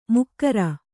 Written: ಮೊಗೇರ

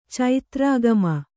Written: ಚೈತ್ರಾಗಮ

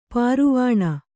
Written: ಪರವಾನೆ

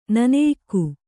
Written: ನೆನೆಯಕ್ಕಿ

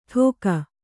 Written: ಢೋಕಾ

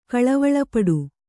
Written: ಕಳವಳಪಡು

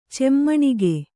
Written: ಚೆಮ್ಮಣಿಗೆ

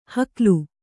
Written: ಹುಗಿಲು

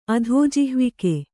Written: ಅಧೋಜಿಹ್ವಿಕೆ

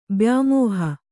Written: ಬ್ಯಾಮೋಹ

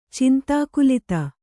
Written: ಚಿಂತಾಕುಲಿತ

